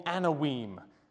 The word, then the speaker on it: Anawim: Anawim. The Anawim. Someone asked last week, how do you spell it? Here it is, A-N-A-W-I-M.